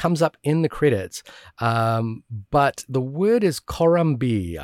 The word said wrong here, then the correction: komorebi: I believe it is. And apologies for my mispronunciation there. Or komorebi.